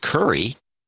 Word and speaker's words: Khoury: Russell Curry, K-H-O-U-R-Y, which I believe is pronounced Curry.